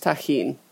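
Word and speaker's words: Tajín: If you can find it, use Tajin. It's spelled T-A-J-I-N.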